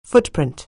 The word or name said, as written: footprint